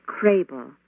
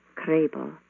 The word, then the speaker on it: Krehbiel: My name before I was married was Krabel, a Swiss-German surname, quite common in Kansas where I grew up. K-R-E-H-B-I-E-L, Krabel.